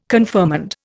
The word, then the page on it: confirmand: Indian